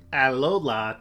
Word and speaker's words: Alola: Well, Alola to you too.